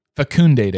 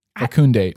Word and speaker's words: fecundate: So to sanctify the womb of the Virgin Mary and divinely fundicate it, fecundate it. Fecundate. – And then I think too, in my pronunciations, it could you could do a long E. Fecundate, I believe.